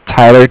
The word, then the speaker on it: Tyler: My name is Tyler, T-Y-L-E-R, and I'm with Bank of Cardiff. That's spelled C-A-R-D-I-F-F, as in Frank.